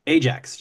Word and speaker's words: Ajax: Ajax, just like the abrasive Ajax.